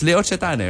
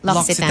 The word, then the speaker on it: L'Occitane: I always thought it was L'Occitane. – O-C-C-I-T-A-N-E. – Well, that's how the Americans say it. L'Occitane.